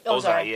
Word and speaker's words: Ozai: You realize that Ozai... Yeah, I said it right? – Ozai, yeah.